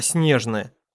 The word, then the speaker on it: Снежное: Дед при жизни, всегда чуть потеплев прищуренной сеточкой вокруг глаз, говорил не так, как принято, а снежное, с ударением на первом слоге.